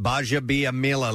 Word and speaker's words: Gbaja-Biamila: G-B-A-J-A-B-I-A-M-I-L-A. – And that's pronounced Baja Biamila.